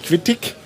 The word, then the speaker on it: quittig: Der Apfel Quittig, ist ganz. – Also mit IG am Ende, was man ja in deutscher Bühnenaussprache ich ausspricht, also Quittig. Also nicht Quittig, sondern Quittig.